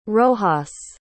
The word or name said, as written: rojas